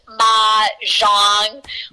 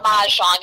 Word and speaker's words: Mah-Jongg: And I will just tell you, it is pronounced Mah-Jongg, like Ma, like M-A, like, you know what I mean? Okay. Mah-Jongg.